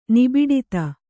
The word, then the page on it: ನಿಬಿಡತೆ: Kannada